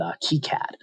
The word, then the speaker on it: KiCad: And I think I mentioned, I think it's actually pronounced, as you said, KiCad.